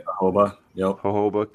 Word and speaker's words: Jojoba: Jojoba? – Yep. Jojoba.